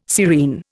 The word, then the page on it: serene: Indian